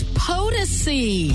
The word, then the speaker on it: Potosi: This hour's wham code word is POTUSI. – POTUSI? – Yes. – Like P-O-T-O-S-I? – That's the one. Now, some of you may say POTUSI. However you say it, spell it. – P-O-T-O-S-I.